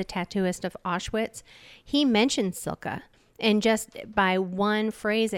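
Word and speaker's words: Cilka: Silke, it's C-I-L-K-A apostrophe S.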